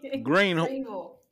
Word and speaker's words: Greenville: Greenville. Spell it again. Spell it. Greenville. Okay. – It's spelled Greenville, but you pronounce it Greenville.